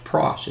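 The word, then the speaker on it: pros (πρός): That little word with right there, with God, is a Greek word pronounced pros. It's P-R-O-S.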